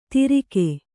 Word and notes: ತಿರಿಕೆ: Kannada

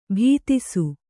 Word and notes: ಭೀತಿಸು: bhītisu

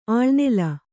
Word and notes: ಅಳ್ನೆಲ: aḷnela

